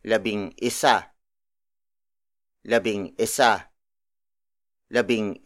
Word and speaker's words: labing isa: So it is pronounced as labing isa, not labing isa.